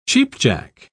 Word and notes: cheap-jack: British English

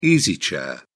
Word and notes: easy chair: British English

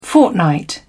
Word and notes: fortnight: British English

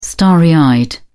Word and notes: starry eyed: British English

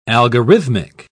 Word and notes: algorithmic: British English

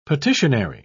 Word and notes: petitionary: British English

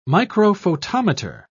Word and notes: microphotometer: British English

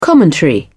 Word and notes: commentary: British English

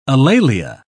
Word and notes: alalia: British English

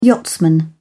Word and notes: yachtsman: British English

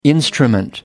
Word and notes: instrument: American English

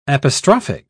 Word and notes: apostrophic: British English

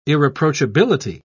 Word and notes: irreproachability: British English

